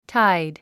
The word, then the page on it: tide: táid; タァイド